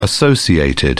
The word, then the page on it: associated: British English